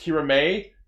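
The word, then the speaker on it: Kiramai: It's, when it comes to the Ranger names, it's spelled M-A-I. – Okay, that's what I was getting to. – It is weird to see it written because it's pronounced Kirame.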